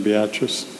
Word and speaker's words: Beatrice: Beatrice.